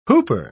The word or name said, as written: whooper